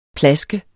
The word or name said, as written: PLASK